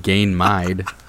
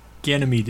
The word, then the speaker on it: Ganymede: I always called him Gain-mide. I'm telling you, I think it's spelled different. – No, Ganymede, it's a moon of Jupiter.